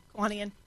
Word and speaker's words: Kiwanian: Also joining us, another banker this morning and another Kiwanin. Is that how you say it? Kiwanin? Kiwanian? – Kiwanian.